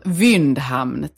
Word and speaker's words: Windhamn: Hur är ditt efternamn, Anna-Karin? Det är så svårt. – Ja, eller hur? Windhamn, tack. Svenskt uttal, brukar jag säga.